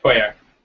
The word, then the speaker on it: Pojer: Christoph, how do you say your last name? – Oh, it's Pojer.